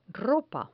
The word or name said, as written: ropa